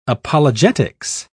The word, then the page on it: apologetics: British English